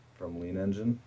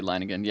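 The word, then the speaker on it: Leiningen: from Lean Engine or Linegan, however you want to pronounce that. – Well, I usually say Linegan, yeah, but there's a lot of confusion around that pronunciation of that particular tool.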